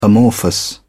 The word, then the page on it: amorphous: British English